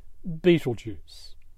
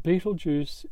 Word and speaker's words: Betelgeuse: As a result of hundreds of years of mispronunciation, the star Ibt Ilyaza is now commonly called Betelgeuse.